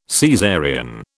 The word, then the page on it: Caesarean: British English